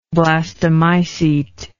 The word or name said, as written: blastomycete